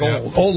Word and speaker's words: Auld: Yeah, it's spelled A-U-L-D, but it sounds like old Lang Syne. Everybody says old.